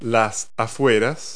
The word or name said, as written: suburb